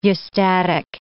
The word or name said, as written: eustatic